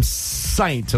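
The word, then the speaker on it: Ssaint: Kiara and Elle from Saint, and I say it like that because it's S-S-A-I-N-T.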